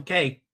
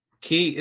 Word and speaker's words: Cay: My only question, if they do something at Rum Key, are they going to pronounce the word C-A-Y correctly for once? It's Rum Key, not Rum Cake, folks. Key is the proper pronunciation of C-A-Y, not like they do it on Coco Cay.